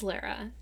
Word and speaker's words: Lara: Golden Ghouls, this is Lara. Since we are all friends now, I will share the pronunciation. It's like Clara without the C.